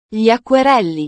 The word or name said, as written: gli acquerelli